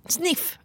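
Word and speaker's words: Sniph: Och jag sa när jag var på ett event nyligen med Snip. Sniff, ursäkta. Sniff. Det stavas Snip och ett H på slutet. Men det uttalas ju Sniff.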